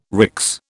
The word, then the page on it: RICS: British English